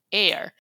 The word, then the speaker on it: heir: A palavra herdeiro em inglês é heir, H-E-I-R, ou H-E-I-R. Só que esse H não é pronunciado.